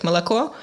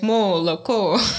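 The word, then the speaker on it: molako (молоко): And for instance, when you say something like milk in Russian, like molako, mm-hmm. Estonian person most probably will say molako.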